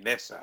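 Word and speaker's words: Mick Nessa: It's Mick and then it's Nessa. – Okay. I'm going to say Nessa because it's the Nessa of two evils.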